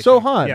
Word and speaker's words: Sochan: Jeremy Sochan. – Sochan. – I think is the correct pronunciation. – Sochan. – Yeah. Well, he's Polish, as we'll get into.